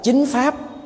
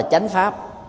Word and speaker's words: chính pháp: phiên âm chuẩn là chính pháp nhưng mà miền Nam không ai đọc là chính pháp mà ta đọc là chánh pháp